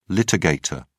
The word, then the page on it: litigator: British English